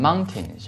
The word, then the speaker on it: mountain: 大山这个单词的话读成 mountain 是吧 T-A-I-N 这个是 tin 不是 ten 哈，就读成 mountain 是吧 mountain 就可以了哈。